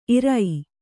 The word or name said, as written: ಇರೈ